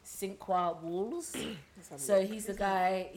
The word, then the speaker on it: Sinqua: S-I-N-Q-U-A. – Cinqua.